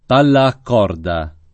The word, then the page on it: palla a corda: lingua italiana